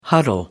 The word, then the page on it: huddle: American English